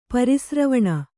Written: ಪರಿಸ್ರವಣ

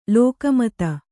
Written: ಲೋಕಮಾತೆ

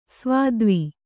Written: ಸ್ವತ್ವ

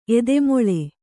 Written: ಏದುಮುಳ್ಳು

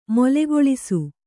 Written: ಮೇಲುಗೆಲಸ

